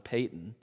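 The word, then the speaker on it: Paton: When I was in America, I found out when I moved here that I mispronounced his name for years and years. John Patton, I always said, and now I know it's John Payton.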